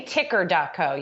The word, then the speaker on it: ticker.co: I don't think there's really a wrong way to say it, but lately I've been pronouncing it as ticker. – Ticker. – It started as TKER, but now it's ticker.